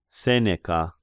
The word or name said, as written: Seneca